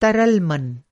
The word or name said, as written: तरल